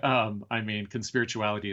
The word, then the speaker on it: Conspirituality: Is that the way you say it, or is it Conspirituality? – We usually de-emphasize the first syllable, but we can talk about why. Conspirituality is good.